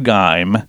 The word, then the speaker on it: Geim: Now, graphene was discovered in 2004 by scientists Andrew Geim and Konstantin Novoselov.